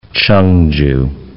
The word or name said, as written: Ch'ongju